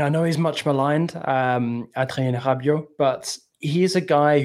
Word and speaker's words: Adrien Rabiot: Adrien Rabiot. You got to get the H sounding R. It's a very French thing.